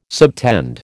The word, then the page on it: subtend: American English